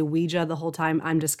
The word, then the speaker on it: Ouija: And I want to say real quick that here in America, we tend to say Ouija board, when I know in other parts of the world, it's Ouija. I think that's the actual correct pronunciation,